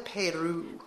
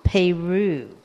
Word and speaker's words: Peyroux: Madeleine, Madeleine Peyroux. Peyroux.